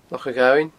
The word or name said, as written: Loch a' Ghobhainn